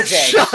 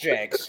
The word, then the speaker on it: Jacques: Number seven, we got a French motherfucker on here because Joe Jacques. – It's Joe Jakes. It's pronounced Jakes. It's Joe Jakes.